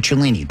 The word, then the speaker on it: Cellini: Download the Fliff app, F-L-I-F-F, and use the promo code Cellini. That would be my last name, and that is spelled C-E-L-L-I-N-I, to get started.